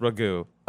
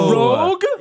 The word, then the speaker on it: Rogue: We were, I think it was until we saw the X-Men cartoon that we're like, oh, it's not pronounced Ragu. Okay. – Ragu. – Rogue? – Yes.